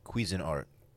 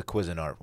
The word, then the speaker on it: Cuisinart: I got the Cuisinart. Is that how you say it? Cuisinart? – I say quiz. – Quiz? – Quiz. – Mine's the Cuisinart one.